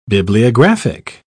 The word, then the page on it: bibliographic: British English